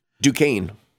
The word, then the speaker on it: Duquesne: I mean, for example, uh, Tony Dalton's Jack Duntanesque is is that how we, we pronounce his name? Duquesne. Duquesne, uh, Jack Duquesne.